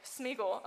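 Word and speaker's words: Sméagol: So this is Schmiegel, right? Did I say his name right? Smeagol. Oh, thank you. This is Smeagol.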